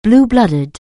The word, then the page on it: blue-blooded: anglais